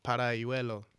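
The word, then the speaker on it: Paralluelo: Paralielo. Suck, man. I know I suck. Help me out here, bro. Honestly, my pronunciations are getting worse and worse, fam. – Parayuelo.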